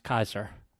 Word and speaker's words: Caesar: Although his name is actually pronounced Kaiser. Julius Kaiser.